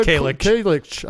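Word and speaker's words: Kajlich: Busta Rhymes, Bianca Kalich.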